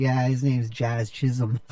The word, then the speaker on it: Jazz Chisholm: And I like Jazz Chisholm a lot, and I love how people mispronounce his name. – It is funny. – Even my mom called me. She's all, who's this Jizz Chasm guy?